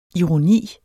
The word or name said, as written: ironi